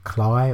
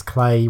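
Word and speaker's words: Klei: I thought it was pronounced either Cly or Clee. I didn't actually know. Apparently, it's Clay rhymes with play.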